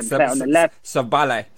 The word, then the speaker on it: Szoboszlai: Oh, Dominic Szoboszlai. – Szoboszlai.